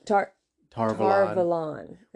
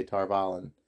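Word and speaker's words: Tar Valon: Yeah, Tar-Valon is how they say it. – In the audio books, they don't say it that way. They say Tar-Valon.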